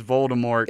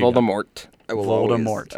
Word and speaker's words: Voldemort: So there you Voldemort. – Go. I will always pronounce the T until I die.